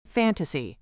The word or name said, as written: fantasy